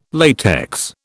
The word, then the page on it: latex: British English